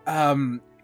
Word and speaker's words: Faz: In Spanish, it's pronounced Faz. So I go based off Spanish pronunciation.